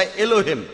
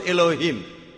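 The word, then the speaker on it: Elah: আর যদি আপনারা বাইবেল পড়েন, অনুবাদ ব্রাদার রেভারেন্ড স্করফিল্ড, তিনি এই এলাকে লিখেছেন ইএলএএইচ, অথবা আরেকটা বানান হলো এএলএএইচ, এলা এলা। তাহলে দেখছেন যে ভাই স্করফিল্ড একমত, উচ্চারণে কিছুটা পার্থক্য, তারা বলে আলা আমরা বলি আল্লাহ, উচ্চারণ কিছুটা আলাদা তবে শব্দ দুটো একই, দুটো শব্দ একই।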